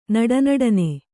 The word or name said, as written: ನಡನಡನೆ